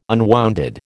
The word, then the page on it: unwounded: American English